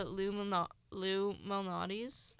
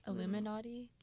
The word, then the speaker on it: Malnati: Wait, what's the Lou place called? Lou Mahali? One sec. I pronounced it wrong. – Is that where? – No, it's called Luminati. Lou Malnati.